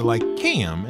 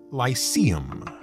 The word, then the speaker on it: Lycaeum: you might recognize the Lycaeum as the great library and center of learning in the lands of Britannia, though you probably pronounced it Lyceum. But remember, in ancient Greek, that C is always hard.